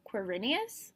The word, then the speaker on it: Quirinus: I mean, I think part of it is that I pronounce that name Quirinius. – Really? Okay, I definitely grew up calling it Quirinus.